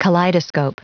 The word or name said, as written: kaleidoscope